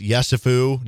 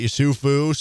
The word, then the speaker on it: Yesufu: Like, I found out that Bobby Pettiford is the best singer on the team and that it is indeed pronounced Yesufu, not Yusufu.